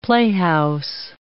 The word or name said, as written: playhouse